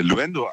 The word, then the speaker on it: Luendo: Schreibt man das U-E-N-D-O? Luendo? – Genau, L-U-E-N-D-O, ja.